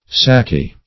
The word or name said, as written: sakieh